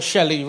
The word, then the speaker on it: Shelley: Or Shelley rhymes with at the bottom of the sea, very smelly.